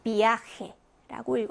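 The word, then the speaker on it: viaje: 그래서 지금 단어 보시면 이와 아가 만났으면 이중모음이고 약강이 만났으면 아를 강하게 읽어주시면 돼요. 그래서 비아헤라고 읽어주시면 되고요.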